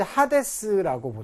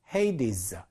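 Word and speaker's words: Hades: 자, 우리말로는 이제 하데스라고 보통 많이 그 읽던데요. 헤이디스 이렇게 읽습니다.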